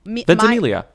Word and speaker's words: Ventimiglia: Ventimiglia. – Milo Ventimiglia. He's okay. Quote, okay. After he's Warner okay. Brothers told him he's too old to play Batman. – He says, Ventimiglia said, they said, after he, quote, threw his name in the ring for this.